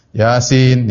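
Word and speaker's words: yasin (يس): Toha, yasin, ya. Dan ini banyak dalam Al-Quran. Ya. Seperti toha, ya. Kemudian apa? Yasin. Dibacanya bukan toh, dibacanya bukan yes atau yes, bukan.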